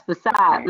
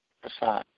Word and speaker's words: facade: Yes, facade. Oh, yeah. – How do you spell that? Facade. – F-A-C-A-D-E. – Oh, I was pronouncing it wrong. Facade. Facade.